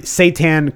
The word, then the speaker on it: seitan: However, what Andy had assumed that would be my favorite was the seitan kebab. Andy, did I say that correctly? – You did.